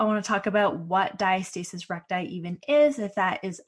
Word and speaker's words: diastasis recti: I say diastasis recti, diastasis recti, diastasis.